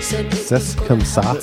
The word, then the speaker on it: C'est comme ça: Well, it's French, so it's, and the way she's pronouncing. I can't even say it. – It's just say. – C'est comme ça. – Yeah, you don't pronounce that. Say.